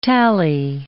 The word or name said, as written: tally